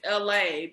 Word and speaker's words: Elhae: Yeah, Masego, then there's this other guy, his name is LA, but it's spelled E-L-H-A-E.